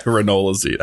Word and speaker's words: ranolazine: So the four categories we have to first talk about are beta blockers, or calcium channel blockers, nitrates, and then ranolazine. I hope I said that right. – Yeah, ranolazine, absolutely.